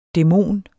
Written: dæmon

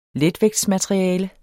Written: letvægtsmateriale

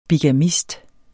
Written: bigamist

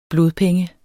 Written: blodpenge

